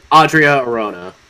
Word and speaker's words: Adria Arjona: Adria Arjona, I think is how you say it. – Mm-hmm. – Adria Arjona.